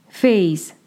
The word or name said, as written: FeyZ